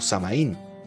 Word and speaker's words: Samhain: En Galicia era común la celebración del Samhain, pronunciado como Samaín,